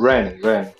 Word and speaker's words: Rennes: Rennes.